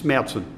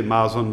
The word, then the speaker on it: Märzen: And there's also this thing, Märzen is not like, the pronunciation of that. It's Märzen. Yes. But that's usually quite okay in English even. Many people say Marzen, but it's okay. It's not as distorted as Rauch.